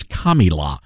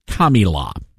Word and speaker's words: Kamala: The correct pronunciation is Kamala. So she, Kamala is her name. Kami, C-O-M-M-I-E, dash, la. Kamala is how you pronounce it.